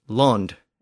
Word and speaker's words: lond: So the original version of the word land was land in the West Saxon dialect, and it was spelled just like we do today, L-A-N-D. But the Anglian dialects to the north pronounced it as lond, and it was spelled L-O-N-D there.